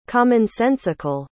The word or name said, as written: commonsensical